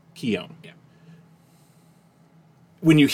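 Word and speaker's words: Keoghan: The Irish pronunciation is Keown, and when I say Keown, it's Keo-n, but it's said fat, quickly, Keown.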